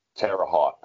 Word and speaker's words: Terre Haute: How's it pronounced? Terry Holt? – Terre Haute. – Terre Haute, yeah, Terre Haute action track.